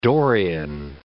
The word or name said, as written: Dorian